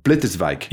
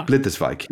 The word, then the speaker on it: Blitterswijck: and it's can you pronounce it for me so I get it right? – Blitterswijck. Yeah. – Blitterswijck. Yeah. – Correct.